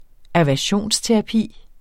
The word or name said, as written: aversionsterapi